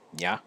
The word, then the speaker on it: Nya: Best way to pronounce it would be Y-A-H, Nya. It's like saying ba, but with a Y. Yeah. It's just very, very short. Again, choppy.